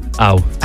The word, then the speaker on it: Au: Au? – Yeah, well, I'm assuming that's how it's... How would you pronounce A-U? – Ow.